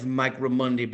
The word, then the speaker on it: Mike Raimondi: My name is Mike Raimondi, baby.